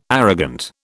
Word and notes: arrogant: British English

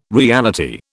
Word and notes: reality: British English

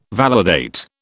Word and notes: validate: British English

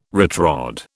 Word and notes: retrod: British English

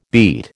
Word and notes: beat: American English

